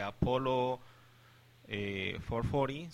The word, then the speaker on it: Apollo 440: Entonces, esto es Stop the Rock de Apollo 440, ¿se dice así? – Sí.